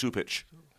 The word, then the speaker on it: Cupich: It's spelled C-U-P-I-C-H. It's pronounced Supich.